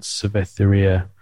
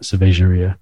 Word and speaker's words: Cervejaria: There's a place in Portugal in Lisbon called Cerveteria. That's not how you say it, Cervejaria uh, Ramiro.